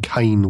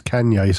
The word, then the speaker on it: Kanye: I've just found out that someone's name isn't Kane West, it's Kanye, so I can't criticize.